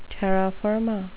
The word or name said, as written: terra firma